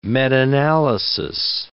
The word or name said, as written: metanalysis